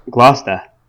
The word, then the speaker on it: Gloucester: Gloucester. I am told is the correct pronunciation. I just think you're a little bit heavy on the R at the end of it. – Well, I am one of those people who has a Boston accent, but it is not non-rhotic. So I pronounce my R's are present and accounted for. It is Gloucester. It is not Gloucester.